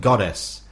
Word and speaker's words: Godus: Apparently it's pronounced goddess. I pronounced it goddess because it's spelled G-O-D-U-S.